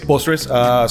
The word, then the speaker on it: postres: Or am I pronouncing this right? Postres? – Yes, postres.